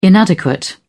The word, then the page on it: inadequate: British English